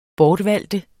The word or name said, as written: BORTVALGT